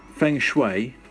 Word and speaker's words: Feng Shui: Feng Shui, am I saying it right? Because we've had this chat before, haven't we? – Yeah.